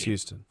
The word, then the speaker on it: Huston: It's either pronounced Houston or Huston. Danny Houston? – Oh, fuck.